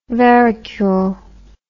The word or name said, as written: verricule